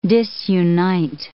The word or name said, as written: disunite